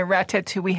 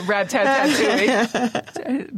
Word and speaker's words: ratatouille: People hear the French chef or Mastering the Art of French Cooking, they're expecting to see maybe a man in a ratatouille hat. Ratatouille. – With a rat underneath. – I mean, a rat-tat-tatouille. Mispronounced that thing.